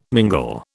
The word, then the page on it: mingle: British English